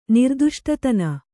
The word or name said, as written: ನಿರ್ದುಷ್ಟತನ